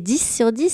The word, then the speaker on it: Dys sur dix: Alors ce spectacle, c'est 10 sur 10. Ça s'écrit D-Y-S sur D-I-X.